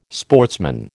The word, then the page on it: sportsman: American English